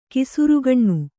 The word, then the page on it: ಕೇಸುರಿಗಣ್ಣು: kannada